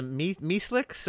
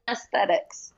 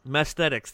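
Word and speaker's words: Messthetics: I can't pronounce the Meeslicks or... Or how is that? – The Mesthetics. – Mesthetics. There we go.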